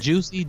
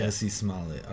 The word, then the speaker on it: Jussie Smollett: Juicy is it juicy? – Juicy. – I don't even know how to say it, but I think it's Jussie Smollett. Yeah, Jussie Smollett.